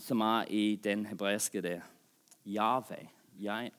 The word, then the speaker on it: Yahweh: Sånn, i hebreisk, Yahweh, som vi uttaler hvordan vi tror det blir, sånn Yahweh.